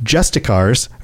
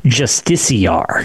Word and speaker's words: Justiciar: So I was going to say, so I always say Justicar or did say Justicar. – Uh-huh. – I've heard it pronounced in game Justiciar. – Justiciar openly expressed. – So I'm going to assume they know better than me because I used to say Justicar, but right. The game Justiciar. Pronounces it Justiciar.